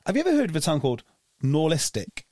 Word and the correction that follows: Norilsk: I think I'm saying it right. And I think it's Norilsk.